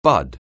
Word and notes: bud: anglais

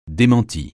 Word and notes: démenti: français

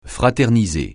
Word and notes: fraterniser: francais

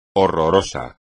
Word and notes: horroroso: espagnol

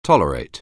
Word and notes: tolerate: anglais

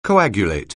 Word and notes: coagulate: anglais

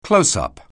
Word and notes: close-up: anglais

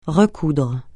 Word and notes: recoudre: francais

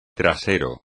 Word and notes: trasero: espagnol

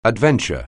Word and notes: adventure: anglais

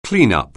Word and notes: cleanup: anglais

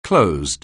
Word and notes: closed: anglais

